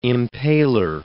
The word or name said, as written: impaler